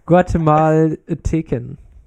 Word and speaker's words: Guatemalteken: Also hier, Guatemala heißt Guatemaleten. Nein, ich kann es nicht mehr aussprechen. Guatemalteken. – Ach so. – Malteken. – Malteken. – Ja, okay. Guatemalteken.